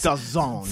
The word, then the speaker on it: DAZN: And then when I looked it up, it said that it's pronounced DAZN. And I went, fuck you. I'm not putting that. I hate you. – So that's the zone.